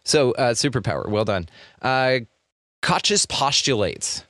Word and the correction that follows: Koch: How did you say that, by the way? Did I nail it? – I believe it's Koch's postulates. Robert Koch. I don't know. He's German, I believe, so I'm not sure what the correct pronunciation is.